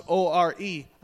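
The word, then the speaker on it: O-R-E (אור): Now, in the Hebrew, the word for light is pronounced O-R-E, and it's spelt the same way as the word for skin.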